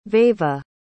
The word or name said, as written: veva